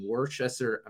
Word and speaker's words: Worcester: That one is in Worcester, Massachusetts, and we're going to go the opposite direction. So I'm going to start. – Apparently it's pronounced Worcester if you're from the Northeast.